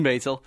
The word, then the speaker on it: Maisel: Maisel.